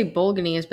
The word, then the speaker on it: Bolognese: Um, I'm going to sit uh, I, Bolognese, How do you say it? I never know how to say it. Bolognese? – I say Bolognese, but that might be wrong. Bol- Bolognese. No, I think that's correct.